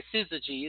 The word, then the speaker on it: Syzygy: Syzygy is the name of the CD. It's spelled S-Y-Z-Y-G-Y.